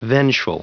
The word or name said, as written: vengeful